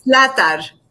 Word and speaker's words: Zlatar: Zlatar. It's a hard one. I get it. I don't think anyone really pronounces it properly.